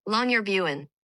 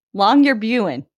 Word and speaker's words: Longyearbyen: Longyearbyen. – Longyearbyen.